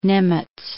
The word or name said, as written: Nimitz